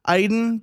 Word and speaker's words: Aidan: And yes, my name is Aidan. It's pronounced Aidan, but also it's I'm your dream come true if you lock me back on social media.